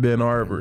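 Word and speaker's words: Benton Harbor: They pronounce that shit Ben Arbor. Ben Arbor. – That, that H, anybody I know from Detroit, they do not pronounce that H. That should be like Ben Arbor.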